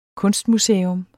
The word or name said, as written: kunstmuseum